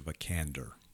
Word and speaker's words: Vikander: Alicia Vikander. – Easy for you to say. – Yeah. – Rhymes with Salamander.